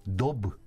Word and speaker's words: daube: Γράφεται D-A-U-B-E, Dub, Dub.